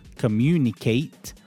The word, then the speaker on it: communicate: Okay, so at the end of a noun or end of an adjective, A-T-E is pronounced as ut, just to make it more accurate. Okay, when A-T-E is at the end of a verb, okay, for example, communicate, A-T-E is pronounced as ate, like the past tense of to eat, ate, communicate. So A-T-E suffix,